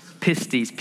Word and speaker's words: pistis: Faith in the Greek is essentially the word, I'm going to mispronounce it, of course, but pistis, P-I-S-T-I-S.